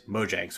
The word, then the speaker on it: Mojang: And we found out it is Mojang, so I've been saying it wrong for forever.